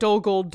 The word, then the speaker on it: Dol Guldur: But the other two, so Faramir is the IR in that instance is pronounced with an EER, so Boromir, Faramir, and then UR is OOR, so OR, so like Dol Guldur Door.